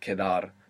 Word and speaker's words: quedar: It's quite a flexible verb, quedar. But for now, to remain or to be left over. Q-U-E-D-A-R, quedar. And Q-U in Spanish is always a K sound.